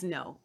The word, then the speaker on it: Ngo: It's just Andy Ngo. – I've heard other people say it. – They don't know how to say it either. Yeah. I just looked the pronunciation up. Okay. It's no. It's okay. Andy no, but it's okay.